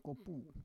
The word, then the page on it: kopu: Roon